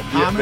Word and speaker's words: homage: Well, homage? Yeah, the H is silent.